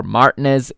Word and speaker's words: Martinez: However, it's bending, but he is denied by the fully outstretched Emiliano Martinez, or Martinez, if you want the British pronunciation.